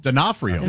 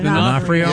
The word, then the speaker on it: D'Onofrio: Born in 1959, Vincent D'Onofrio? – D'Onofrio. – D'Onofrio.